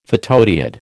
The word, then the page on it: photodiode: American English